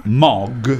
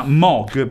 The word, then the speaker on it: Moog: Che si pronuncia Moog. Si pronuncia Moog perché fu progettato da Robert Moog, che è un signore, quindi.